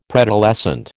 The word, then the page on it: preadolescent: American English